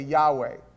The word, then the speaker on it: Yahweh: You notice no vowels appear, YHWH, pronounced phonetically Yahweh.